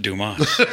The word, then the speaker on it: Dumas: It's pronounced Dumas.